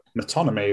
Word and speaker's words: metonymy: and from your chapter from Metaphor to Metonymy. It was the first time I said that word, so I've almost 100% mispronounced it. – That's actually right.